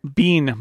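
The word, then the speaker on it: Biehn: Is it Bean? – Bean.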